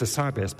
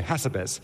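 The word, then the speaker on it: Hassabis: It's demis acerbis, but they say hasabis. – Yes. – And I say, no, you have to put emphasis on the right syllable.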